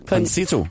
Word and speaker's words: Concito: Og det har vi talt med ham her, Jarl Krausing, der er international chef i tænketanken Concitli. Concito. – Concito, ikke Concito. Sorry, jeg havde allerede glemt, hvordan man udtalte det igen.